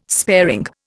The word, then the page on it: sparing: Indian